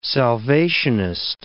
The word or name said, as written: Salvationist